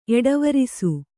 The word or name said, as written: ಎಡೆವರಿಸು